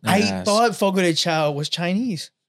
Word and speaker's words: Fogo de Chão: It's actually pronounced Fogo de Chão. – Is it? – It's spelled chow. It's spelled chow.